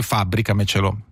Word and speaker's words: fabbricamecelo: Uno ti chiede, ti fabbrico il gabbiotto degli strumenti nel giardino e tu gli rispondi fabbricamecelo. che tra l'altro è una parola con accento sulla sest'ultima sillaba, quindi quadrisdrucciola, credo che sia l'unica nella lingua italiana.